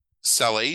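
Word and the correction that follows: Salles: is that how you say it? Sales? I don't know. – Salus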